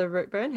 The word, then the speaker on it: Routeburn: Uh, the Tongariro uh, Alpine Pass, um, and uh, the Rotter is it the Rotterburn Rotterburn Track? – Oh, is that the Routeburn? How do you say Routeburn? Sorry, Routeburn. Yeah, yeah, yeah. I'm yeah. pronouncing it wrong, but the Routeburn was definitely on the list.